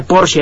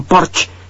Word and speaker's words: Porsche: Un Ferrari, bueno, mi modo de ver, los Porsche son mejores, y se pronuncia Porsche, algunos que dicen Porsche. Porsche es alemán.